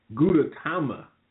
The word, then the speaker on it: Gudetama: In the Yata grocery store, a character called Guduma. No, I said that wrong. Gudetama.